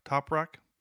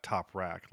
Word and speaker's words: Toprak: Toprak? Not top rack, like, you know, your shoes.